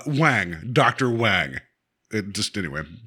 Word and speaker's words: Wange: You could give them any last name, and you write Wang, W-A-N-G-E. Like, how do you pronounce that? Wangy? – I'm like, well, I don't want to say Wang on here, but I guess it is. But Wang, but the E is silent, you know.